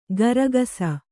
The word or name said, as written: ಗರಗಸ